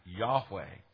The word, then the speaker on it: Yahweh: Now, it's because of an attempt to make that work out that generations of Christians pronounced the name of the Lord, the covenant name, as Jehovah. Jehovah is probably the closest way of making the Adonai vowels fit with the consonants. But I think there's a fairly unanimity among scholars today that the best understanding we can have of the pronunciation is, in fact, Yahweh.